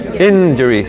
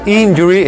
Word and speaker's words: injury: Recuerden que la, la i de nosotros, la i de nosotros que se pronuncia i grande, en inglés es más cerrada, in. Injury, ¿cierto? No es injury, es injury. ¿Cierto?